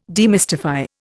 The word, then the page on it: demystify: Indian